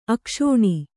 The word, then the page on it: ಅಕ್ಷೂಣ: akṣūṇa